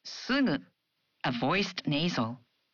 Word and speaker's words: すぐ: In Japanese, you pronounce gu in the initial syllables of words as a voiced gu, like ga, gi, gu, ge, go. But for other syllables, such as sugu, you pronounce the gu sound as a nasal.